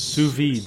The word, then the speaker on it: sous vide: I can never pronounce it, but it's... – Sous vide. – Sous vide, French for under vacuum.